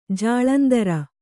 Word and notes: ಜಾಲಂದ್ರ: jālandra

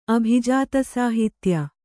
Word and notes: ಅಭಿಜಾತಸಾಹಿತ್ಯ: abhijātasāhitya

